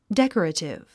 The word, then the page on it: decorative: dék ə rətiv; デェコラティブ